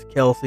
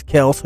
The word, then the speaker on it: Kelce: Yeah, I know I called him Travis Kelce in the last episode, but I looked it up. I've actually been pronouncing it wrong. She's dating Travis Kelce, who's about to go to the Super Bowl, which is tomorrow.